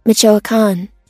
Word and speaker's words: Michoacán: Michoacán. – Michoacán.